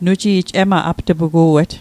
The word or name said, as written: apt'pugu'et